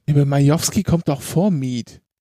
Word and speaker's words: Mieth: Nicole Mief. Miet? Wie wird das ausgesprochen? – Schreibt man es denn? – M-I-E-T-H. – Dann wahrscheinlich Miet. – Ja. Miet, okay.